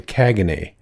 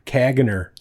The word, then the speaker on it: Caganer: Caganay. And I think that's how you pronounce it, but it's spelled C-A-G-A-N-E-R. So, I mean, I would call it like Caganer. – Caganer. – But it's, I think they say Caganay.